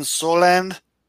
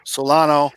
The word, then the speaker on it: Solano: Soland, or is that Solano? Solano.